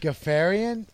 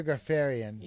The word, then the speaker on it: Ghafarian: G-H-A-F-A-R-I-A-N. It might be Pierre Perry. – G-H-A? – That's what I said twice. – The H is silent. – How would you so say this name? – Gaffarian? – Gaffarian? – I can't possibly... Wait. Yeah, Pierre Gaffarian. – That sounds better.